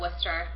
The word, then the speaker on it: Worcester: The Hope Coalition is from Worcester, Massachusetts. Yes, that's how you pronounce it.